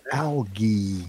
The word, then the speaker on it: algae: It's spelled with a it's a G. – Yeah. – It's not a J. It's algae. – Well, in that case, then it should be algae. – Oh. – Because F-A-E is the fey, and G-A-E would be gay.